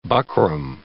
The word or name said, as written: buckram